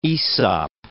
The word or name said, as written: Aesop